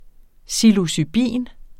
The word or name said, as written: psilocybin